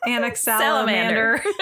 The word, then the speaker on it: Anaximander: Well, XI usually turns into a Z sound, though. – Anaxi? – No, Anazi. The X is silent Anazi. If it's a Z. – Anaximander. I think it's Anaxi. – Anaxi? – Yeah. – Where are you turning? Okay. Sure. So, Anaximander. Anaximander. Yes.